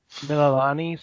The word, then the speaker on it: Mililani: Mililani, something like that. They pronounce their vowels different. – I think you got it, though. I think that sounds right.